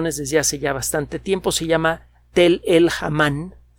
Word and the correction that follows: Hammam: Hamam. Eh, se escribe Tel, T-E-L-L, espacio E-L, guión, H-A-M-M-A-M. Eh, Tel o Tal el Hamam se encuentra en Jordania.